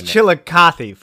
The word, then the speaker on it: Chillicothe: So we're getting close to Chillicothe. We know that has a Walmart. – Hold on. It's Chillicothe, fool.